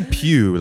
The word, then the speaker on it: Pugh: Florence Pugh. I'm probably. – I say pew, like a church pew.